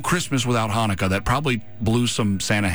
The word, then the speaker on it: Hanukkah: So Hanukkah works. It's for an English mouth, that's about as close as you're going to get, because you'll sometimes see it spelled with a CH instead of an H.